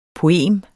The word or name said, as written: poem